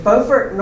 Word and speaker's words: Beaufort: So after American independence, there was a fellow, Jacob Henry, from, help me with my pronunciation, Beaufort, North Carolina.